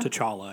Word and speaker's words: T'Challa: T'Challa? – T'Challa, I think.